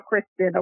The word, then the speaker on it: Kristen: This is the only real way to spell Kristen, okay? K-R-I-S-T-E-N. E-N. Like 10, perfect 10.